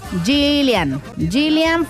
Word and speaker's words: Gillian: Gillian Fuentes 15.